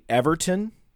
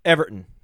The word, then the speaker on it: Everton: Like, it's not actually Everton. That's how Americans say it. It's Everton. You say it so fast, it's like all one syllable, Everton.